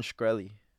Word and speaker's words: Shkreli: Martin Shkreli. – Martin Shkreli. Okay, so. – I'm just sounding it out. I don't know. – Bro, I don't know this motherfucker's name. Martin Shkreli.